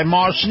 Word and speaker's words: Maus: Moss Nissan. Now, Moss is spelled M-A-U-S, and Moss Nissan is the home of the all-new 2021 Rogue.